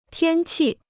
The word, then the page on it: 天气: tiān qì